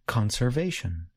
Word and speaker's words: conservation: In the noun conservation, I stress the first part of the word. Listen again. Conservation.